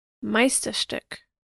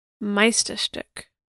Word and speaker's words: Meisterstück: Here's how to say it. Meisterstück. Meisterstück.